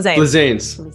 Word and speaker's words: Flizanes: Am I saying that right? Flizanes? It sounds okay. Well, Flizanes. – I want to throw a little Spanish. – Well, it's Italian. It's actually Greek.